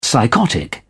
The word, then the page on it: psychotic: British English